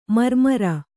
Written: ಮಿರಿಮಿರಿ

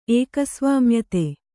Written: ಏಕಸ್ವಾಮ್ಯತೆ

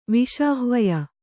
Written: ವಿಷಾಹ್ವಯ

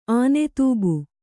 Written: ಆನೆತೂಬು

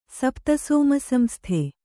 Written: ಸಪ್ತಸೋಮಸಂಸ್ಥೆ

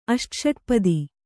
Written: ಅಷ್ಟಷಟ್ಪದಿ

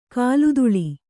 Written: ಕಾಲ್ದೂಳಿ

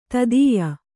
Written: ದೈತೇಯ

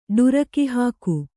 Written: ಢರಕಿಹಾಕು

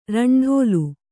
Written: ರಂಡೋಲು

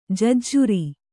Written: ಜಜ್ಜರ

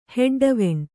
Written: ಹೆಡ್ಡವೆಣ್

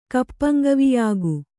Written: ಕಪ್ಪಂಗವಿಯಾಗು

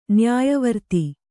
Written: ನ್ಯಾಯವರ್ತಿ

